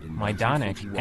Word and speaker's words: Majdanek: On the edge of the city of Lublin, you can still find large parts of Majenik, the largest concentration camp outside of the German Reich. – The announcer repeatedly mispronounces the name of the camp, Majdanek, as...